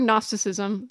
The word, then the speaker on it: Gnosticism: And it, the word Gnosticism, spelled G-N-O, silent G at the beginning, comes from the Greek word gnosis, which means knowledge.